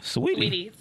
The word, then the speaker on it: Saweetie: How you say her name? – Sweetie. – I thought it was Saweetie.